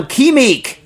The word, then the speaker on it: QimiQ: Okay, Alan Sternberg writes in on Kimik, which is spelled Q-I-M-I-Q. Dumb, right? Q-I-M-I-Q. I think it was invented by an Austrian. Kimik.